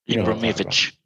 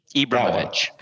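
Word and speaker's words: Ibrahimović: Ibrahimovic. Ibrahimovic.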